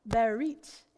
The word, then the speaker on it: berit: When God called Noah to build the ark in Genesis 6, 18, he used the word covenant pronounced berit in Hebrew.